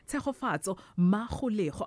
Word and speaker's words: Tshegofatso Magolego: We decided to celebrate queer focused children's book author Tshegofatso Magolego.